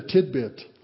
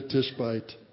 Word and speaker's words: Tishbite: Now, I have to tell you, years ago, I preached a whole message on Elijah the Tidbit. I had mispronounced that, so don't make that mistake. He was not a tidbit. He was Elijah the Tishbite.